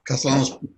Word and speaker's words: Castellano: Castellano's just fine. – Well, how do you say it? – Well, actually, it's a Spanish name, so it's Castellano.